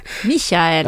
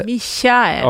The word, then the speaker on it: Michael: Och Jack Doohan började köra go-kart i en go-kart som han fick av Michael Schumacher. – Michael. – Ja, ja, förlåt. Jag skäms alltid över mina tyska uttal.